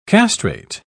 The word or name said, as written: castrate